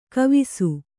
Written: ಗವಿಸು

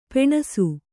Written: ಪುಣ್ಸೆ

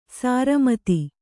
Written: ಸರಮುತ್ತು

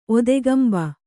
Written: ಒತ್ತುಗಂಬ

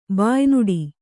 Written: ಬಾಯ್ನುಡಿ

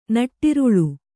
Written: ನಟ್ಟಿರುಳ್

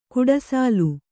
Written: ಗುಡಿಸಿಲು

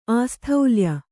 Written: ಆಸ್ಥೌಲ್ಯ

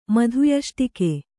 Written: ಮಧುಯಷ್ಟಿಕೆ

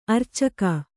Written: ಅರೋಚಕಿ

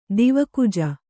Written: ದೇವಕುಜ

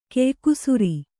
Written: ಕೆಯ್ಕುಸುರಿ

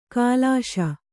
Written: ಕಲುಷ

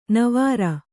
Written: ನೇವುರ

